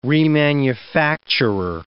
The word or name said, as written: remanufacturer